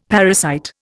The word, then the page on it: parasite: Indian